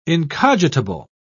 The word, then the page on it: incogitable: British English